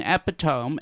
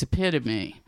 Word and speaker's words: epitome: But it could have been calamity, just like it could have been epitome. And by English rules, it should have been epitome, because you have a vowel in between two consonants, so it should be a long O. But because it's English and every rule has like 45 exceptions to it, it's epitome.